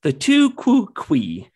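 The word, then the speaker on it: tu quoque: The Tukukui. – How do you spell this? – It's T-U as one word, and then Q-U-O, Q-U-E as one word. – Tukukui? – But it basically means you also.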